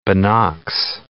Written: binocs

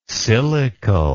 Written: silicle

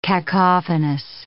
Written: cacophonous

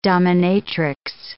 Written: dominatrix

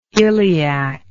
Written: ileac

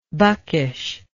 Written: buckishness

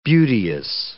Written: beauteous

